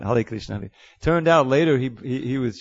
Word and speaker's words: Hare Krishna: Hale Krishna.